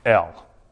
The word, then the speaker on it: El: Now, in the Hebrew, transliterated into the English, God is pronounced El. E-L.